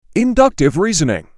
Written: Inductive reasoning

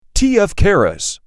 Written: Keras

